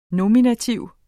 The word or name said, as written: nominativ